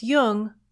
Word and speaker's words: Jung: Just be sure to pronounce Jung with a hard J. So with special thanks to Peter Ammann, this is Laura London, and you've been listening to Speaking of Jung.